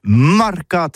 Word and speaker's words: marcat: Eh bien, dis ça à tous, mais le mercat de l'Uni ou le mercat des rebelles, mais en gascogne toulousain, c'est prononcé les marcat avec un A.